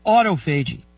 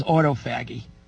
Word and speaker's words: autophagy: Water fasting takes advantage of a phenomenon in the cell called autophagy. That's how I pronounce it. Somebody else says autophagy. I don't like that word. Autophagy. A-U-T-O-P-H-A-G-Y. Say it any way you want.